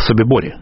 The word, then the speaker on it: Собибор: Я имею в виду восстание в Собиборе. Собибор или Собибурово по-разному произносят, лагерь уничтожения на территории Польши, восстание, которое возглавил офицер Красной Армии Александр Печерский.